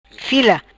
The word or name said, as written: Fila